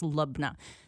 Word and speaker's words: labneh: And then our student who studied in, or our coworker who studied in Jordan this spring told me it's pronounced Lubna.